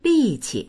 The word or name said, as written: li4qi